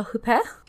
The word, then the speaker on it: Huppert: We begin by meeting our main character, Erica Cohut, and she is played by Isabelle Huppert, who's a French actress. That's why you don't say the T at the end, but please tell me if I'm mispronouncing the H part, because I'm always, like, confused if I did.